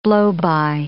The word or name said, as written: blowby